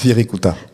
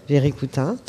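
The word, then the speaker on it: Wirikuta: Viricuta. – Viricuta.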